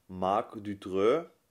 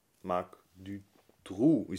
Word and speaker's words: Marc Dutroux: Also falsch ausgesprochen, so wie wir es, glaube ich, immer gesagt haben, ist Marc Dutreux. Marc Dutroux ist richtig.